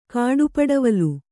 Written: ಕಾಡುಪಡವಲು

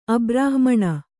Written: ಅಬ್ರಾಹ್ಮಣ

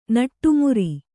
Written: ನಡುಮುರಿ

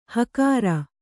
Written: ಹಕಾರ

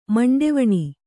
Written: ಮಂಡೆವಣಿ

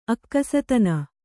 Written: ಅಕ್ಕಸತನ

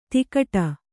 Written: ದುಕ್ಕುಡಿ

